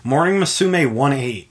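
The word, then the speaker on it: Morning Musume '18: And then Morning Musume 1-8. Because I found out it's pronounced 1-8, not 18, for some reason. Because it's a Japanese thing, I guess.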